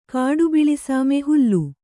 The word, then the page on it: ಹಲಿ: kannada